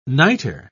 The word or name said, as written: niter